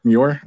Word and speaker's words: Muir: Hopefully I'm saying that right. I feel like I never say that island's name right. Muir Island. That's what like, the animated series calls it as. And I always use my animated series pronunciations.